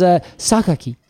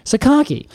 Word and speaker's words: Sakaki: And I learned how to say this name. I looked it up on the internet, as I am wont to do. And it is Sakaki.